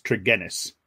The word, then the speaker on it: Tregennis: Like, I hate to correct you, but it's a hard G, so it's Tregennis.